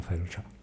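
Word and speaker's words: fáilte: L'unica cosa curiosa delle due cose è che uno si pronuncia slainte, si pronuncia sloncia, uno si pronuncia failte, si pronuncia felcia.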